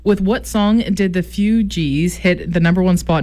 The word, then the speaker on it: Fugees: It's the Fugees.